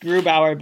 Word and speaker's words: Grubauer: Or do you think that maybe for this season, he's no longer considered the best one and Grobner is the one to grab? – Actually, Ilan, I think it's pronounced Grubauer, but of course, I have my own troubles with that sort of thing.